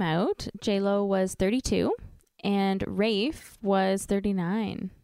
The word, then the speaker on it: Ralph: Maybe it's because his name is spelt Ralph, but he makes people pronounce it Rafe.